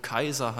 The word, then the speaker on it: Cäsar: Und davor waren unzählige Könige, bis zurück zum Cäsar, der ja eigentlich lateinisch ausgesprochen Kaiser heißt.